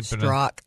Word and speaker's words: Strzok: Paige is the mistress of Peter, is it Stork? Is that how you pronounce it? – Strzok.